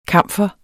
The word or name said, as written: kamfer